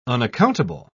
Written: unaccountable

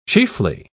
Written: chiefly

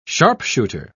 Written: sharpshooter

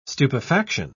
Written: stupefaction

